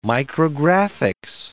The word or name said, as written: micrographics